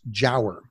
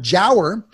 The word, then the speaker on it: giaour: The word is jower. It's spelled G-I-A-O-U-R, and it's pronounced Jower. – Jower, I don't know it. – G-I-A-O-U-R. A lot of vowels there. – G-I-A-O-U.